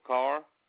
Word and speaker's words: Carr: Donald Carr, C-A-R-R.